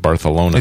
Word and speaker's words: Barcelona: Yeah, the people in Barcelona pronounce it Barthelona.